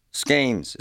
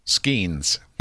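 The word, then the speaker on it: Skenes: Skeens. Is that how you say it? – Mm-hmm. – Skeens.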